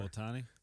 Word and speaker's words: Otani: Otani.